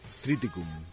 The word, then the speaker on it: Triticum: Triticum.